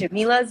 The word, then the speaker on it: Jamela: Jamila Zara, and they're both, my first and middle name are Arabic. So I get a lot of people who are like, how do I say this? And then I get in a cab, and they're like, oh, Jamila, my mom's name is Jamila. – Oh, really? – So, yeah, it's Arabic, so all my Arab brothers and sisters, they know how to say it immediately.